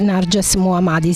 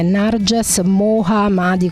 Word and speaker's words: Narges Mohammadi: Narges Mohammadi. Sì, lo dico proprio così perché si scrive così, il suo nome si scrive Narges Mohammadi con un H nel mezzo.